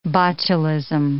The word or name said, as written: botulism